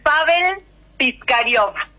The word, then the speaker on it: Pavel Piskarev: Te lo digo en una pronunciación que nosotras podamos comprender y ¿sí? repetir, como argentinas. Pavel Piskarev. – Sí, Beta nos da la pronunciación adecuada, perfecta en ruso, pero para nosotras hay sonidos que todavía son difíciles.